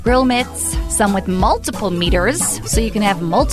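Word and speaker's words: Meater: By the way, meters is spelled M-E-A-T-E-R-S, like meat-ers, get it?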